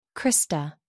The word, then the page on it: crista: American English